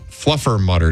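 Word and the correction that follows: Fluffernutter: Day. – Nope. Try again. – Not for you? – You're still saying it wrong. – Oh, Fluffer Nutter Day.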